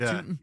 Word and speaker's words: Tooting: Is it Tooting? – Yeah.